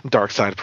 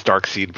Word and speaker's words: Darkseid: You know, I have seen heard Dark Side pronounced as Dark Seed before. Like, in an official DC cartoon. – Nobody does that. – Yeah. Nobody does it now because it's wrong.